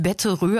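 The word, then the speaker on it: Betterov: Betteroff oder Röff? Es wird irgendwie eigentlich Betterö ausgesprochen.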